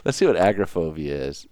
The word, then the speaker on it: agoraphobia: And I'm not sure you're saying it correctly. It's agoraphobia. – I'm pretty sure it's agoraphobia. – I think you're putting the emphasis on the wrong word. You have the tendency to do that. – Are you telling me that I put the emphasis on the wrong part of the word? – You have the tendency to put the emphasis on the wrong syllable.